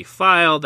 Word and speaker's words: Feil: Feil.